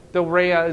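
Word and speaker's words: Doreia (δωρεά): Doreia is the way I'd pronounce it.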